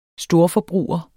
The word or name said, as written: storforbruger